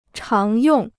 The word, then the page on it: 常用: cháng yòng